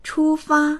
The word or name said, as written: chu1fa1